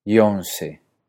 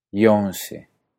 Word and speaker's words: Jónsi: Entonces, Jónsi se pronuncia en islandés Jónsi. Jónsi. Jónsi.